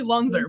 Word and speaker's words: Lanser: Uh, it's it's actually Lunzer, mm.